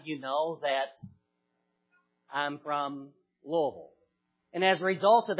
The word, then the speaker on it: Louisville: It is Louisville. Say it as fast as you can. Try to say it with one syllable if possible.